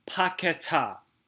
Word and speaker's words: Paquetá: And for the love of God, the name pronunciation, Paquetá. Say it with me, Paquetá. It's not that hard. It's not Paquetá.